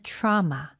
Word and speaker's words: trauma: 오, 발음할 때 트라우마가 아니네요. – 맞아요. 이거 약간 사우나하고 비슷한 느낌이네요. – 맞네요. – 사우나도 sauna. – Right. – 트라우마도 trauma. – Trauma. – Trauma. – Okay. – Drama하고 똑같아요.